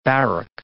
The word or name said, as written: barrack